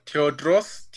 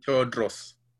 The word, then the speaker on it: Tewodros: Teodros, T-E-W-O-D-R-O-S, Teodros. Gebremeskel, G-E-B-R-E-M-E-S-K-L.